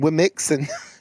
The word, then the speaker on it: WOMXN: But you know, I want to give an example of something that is trending right the second, which is women spelled with an X instead of the E. So WOMXN. And this came about because Twitch, the streaming platform that a lot of DJs go on, announced that women, WOMXN, Wimixen, was an inclusive term that meant women and trans.